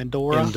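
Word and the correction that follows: Indara: Endara, okay.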